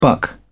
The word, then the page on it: buck: British English